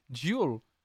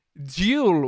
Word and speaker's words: Jewel: Comment on prononce Jules Jules Jules Non, pas le J J-E-W-E-L Joule. Jill, Joule. Joule. Jill, Jill, Gilles, Gilles, oui, Joule. oui c'est ça, c'est Joule.